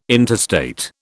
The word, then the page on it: interstate: British English